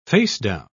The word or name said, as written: facedown